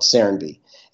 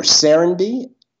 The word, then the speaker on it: Serenbe: Say the name again, or maybe spell it if you can. – Sure. Serenby. S-E-R-E-N-B-E. – Serenby.